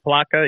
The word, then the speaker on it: Palatka: Palatka, yeah, north of Daytona by about 45 minutes.